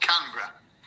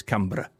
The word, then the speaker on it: Canberra: Not Can Edinburgh, but Canberra.